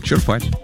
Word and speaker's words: черпать: Черпать. Ну, почему нет? На самом деле, вот словари все почти указывают совсем другое. Правильное ударение на первый слог. Этот вариант зафиксирован в большинстве нормативных словарей. Черпать на «е».